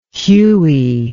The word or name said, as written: Huey